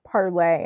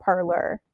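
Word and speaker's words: Parler: I found out today it's pronounced Parlay and not Parlor.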